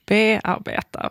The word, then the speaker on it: bearbeta: Och varför tar du då helt plötsligt betoning i bearbeta? Varför säger vi inte bearbeta? Som vore det rimliga. Alltså din tyska föreläsare, han sa ju egentligen det som var rimligt. Och det är vi alla vi andra som har liksom gjort ett uttalsundantag just för det här ordet.